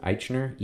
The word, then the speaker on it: Eichner: Because according to Billy Eichner, I think that's how you pronounce it, Eichner, E-I-C-H-N-E-R, I believe, who's one of the producers and the star of this new gay rom-com, right?